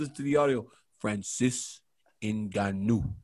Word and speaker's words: Ngannou: Francis Ngannou.